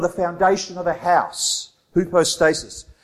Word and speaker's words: hypostasis: That word substance in the Greek is hypostasis. That's how you pronounce it in the Greek, but I'm saying it with an Australian accent. So it's hypostasis, that word substance, the substance of things hoped for.